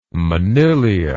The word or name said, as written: monilia